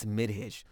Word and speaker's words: Midhij: But in Arabic, it's midhij. – Midhij.